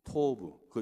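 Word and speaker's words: 토브 (טוב): 그런데 이 땅의 이름이 돕이라고 했습니다. 히브리어 발음 그대로 읽으면은 토브입니다. 토브, 그땅 이름이.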